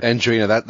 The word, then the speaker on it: Adrienne: but is it Andrina Roy or A-D-R-I-E-N-N-E? How would you say that? – Andrina, that's how I put it.